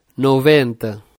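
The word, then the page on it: 90: Portuguese; Brazilian Portuguese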